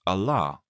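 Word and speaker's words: Allah (الله): It is pronounced Allah with a long vowel A.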